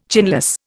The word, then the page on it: chinless: Indian